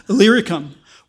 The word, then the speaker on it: Illyricum: Illyricum, which doesn't get mentioned.